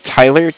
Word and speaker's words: Tyler: Okay, my name is Tyler, T-Y-L-E-R, and I'm calling with Cardiff. It's spelled C-A-R-D-I-F-F, as in Frank.